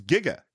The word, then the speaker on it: giga: Apparently, Zemeckis and Gale interviewed a scientist who pronounced it gigawatts, and they wrote it in a script with the J. Oh, that's so funny. And they always pronounce it that way until later on, everyone's like, that's it's giga. Giga is you know like gigabits. – Yeah. Giga giga is the actual word.